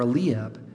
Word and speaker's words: Eliab: He brings Eliab out, or Eliab, as you may have heard it pronounced.